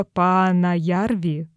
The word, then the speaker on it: Паанаярви: Я сейчас э, погуглила а, вот эту локацию, которую ты сказала, Панаярви, Панаярви. как правильно ударение сюда? – На первый слог, вот я сегодня специально смотрела, как ставится ударение, оно ставится на первую А, вот так вот, Пана Ярви.